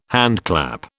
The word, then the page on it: handclap: British English